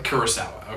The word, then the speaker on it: Kurosawa: And that's Kurosawa, yeah. – Kurosawa, okay.